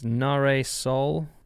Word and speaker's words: Nahre Sol: I will spell out her name because I will probably mispronounce it. It's Nahre Sol. N-A-H-R-E space S-O-L.